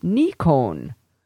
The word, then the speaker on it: Nikon: Regarding the Nikon pronunciation homework assignment, in Japanese, it would sound something like Nikon. If you're, or let's see, he says slash N-I-K-O-N slash if you prefer.